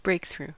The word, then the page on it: breakthrough: American English